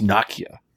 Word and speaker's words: Nokia: And the other bit of news, Nokia is actually pronounced Nokia.